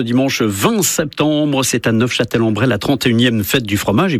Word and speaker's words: Neufchâtel-en-Bray: Et en effet, pour les puristes, dont je ne suis pas, puisque je n'ai pas prononcé le F, pour les puristes, en effet, il faut prononcer Neufchâtel-en-Bray pour, en effet, peut-être se différencier de la ville de nos amis suisses.